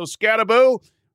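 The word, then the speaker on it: Skattebo: And I have to correct my pronunciation. Cam Scataboo, not Scatabo. Their running back, Doug Tamaro, corrected me on that. So Scataboo, who ran for 260.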